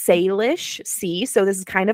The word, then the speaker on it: Salish: S-A-L-I-S-H. – Yeah, but it's pronounced like say, like S-A-Y. So Salish.